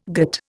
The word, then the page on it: gîte: Indian